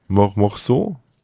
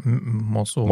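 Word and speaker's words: Meursault: Und wir lernen dort einen jungen Mann kennen, einen Franzosen, der heißt, jetzt muss ich nochmal gucken, wie er heißt, Morceau, so wird er ausgesprochen. – Das stimmt auf jeden Fall, Morceau.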